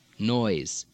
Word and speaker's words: noise: Noise. – Noise. – Tries. – Tries. – Repose. – Repose. – Frequently, of course, this is spelled with an S, but in English pronounced Z.